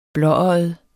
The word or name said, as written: blåøjet